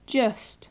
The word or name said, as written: just